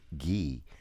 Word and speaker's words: ghee: My question is, the recipe book I have, which is years old and British, often uses ghee.